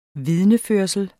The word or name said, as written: vidneførsel